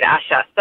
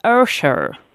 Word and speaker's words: Usher: I heard him very clearly correct the host and say, it's not Usher, it's Usher.